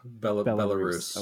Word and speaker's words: Belarus: Is it pronounced Belarus? Have I been pronouncing it wrong this entire time? – Yeah, Belarus.